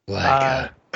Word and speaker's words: Leica: How do you pronounce that? – Leica. – Thank you.